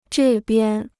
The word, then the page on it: 这边: zhè biān